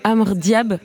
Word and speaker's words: Amr Diab: C'est l'icône égyptienne Amr Diab. J'espère que je prononce bien. – Oui, c'est ça.